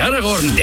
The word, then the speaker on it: Aragón: Gran premio de Aragón. De Aragón, de Aragón, no de Aragón. Aragón con acento en la O.